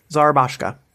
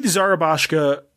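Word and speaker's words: Szarabajka: How did you say this guy's name? Keith Serbajacki? Serbajack? – Let me scroll back up. Zarabashka. – Keith Zarabashka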